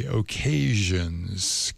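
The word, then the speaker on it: O'Kaysions: And we started off with a group called, I'll spell it for you, the O. Parenthesis K with a K, Occasions, K-A-Y-S-I-O-N-S, the Occasions.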